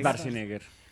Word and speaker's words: Schwarzenegger: Es Schwarzenegger. – Ah, sí. Pero entonces todos los doblajes de las películas son mal. – Mal, mal, mal pronunciados.